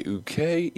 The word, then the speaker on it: Uke: My name is James, and joining us today, Uke Ikang, the health and wellness coach. How are you? – I'm good. It's pronounced UK. – UK. – It's like the letters UK Ikang or Coach Ikang.